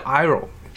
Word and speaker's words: gyro: 其实最著名的就是 gyro，它其实那个词 g y r o gyro，它其实好像 g 是不发音的，它应该叫 iro，是什么东西呢？